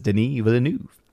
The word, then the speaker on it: Denis Villeneuve: I'm pretty sure that is the accurate way of pronouncing it, because I remember I was looking up how to do a correct pronunciation for it, because I am a huge fan of his work, and so ever since, I've always just pronounced it as Denis Villeneuve.